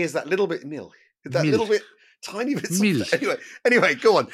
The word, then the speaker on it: Milch: how I should pronounce his name. – Right. – Was it Milch or Milch or Milk? And me and my producer, we went backwards and forwards for the length, 600 pages. Is it Milch, Milch or Milk? And Milch. and it just went, because generally the German pronunciations... Milch. They're all right, but it's just one name. And I think we ended up calling him Milk. – I've always called him Erhard Milk.